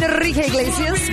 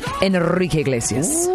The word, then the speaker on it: Enrique Iglesias: Enrique Iglesias, be with you on East Coast Radio. That's how you say it, Jay Spoon.